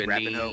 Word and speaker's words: Rapinoe: They were like mad that what's her name, Rapione or whatever, like took a Rapinoe.